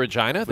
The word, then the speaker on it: Regina: You went to Regina? – The Regina? Yep. Yeah. – The city that rhymes with fun.